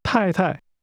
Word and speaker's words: 太太: That's pronounced with two falling tones. Say again, wife. – 太太。